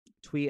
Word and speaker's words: Tui: Tui and Law.